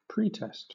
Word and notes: pretest: British English; noun

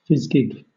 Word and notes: fizgig: British English

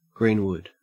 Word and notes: greenwood: Australian English

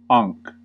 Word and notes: ankh: American English

